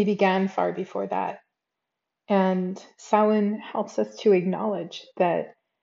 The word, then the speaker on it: Samhain: It's not pronounced Samhain, it's Samhain.